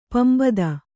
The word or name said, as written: ಪಂಬದ